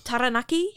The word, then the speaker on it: Taranaki: That's Taranaki is what it's called, isn't it? – Taranaki. That's the proper Maori pronunciation.